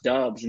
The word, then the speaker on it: Doubs: Well, it's actually pronounced Dubs, and that's solely why.